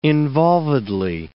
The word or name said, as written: involvedly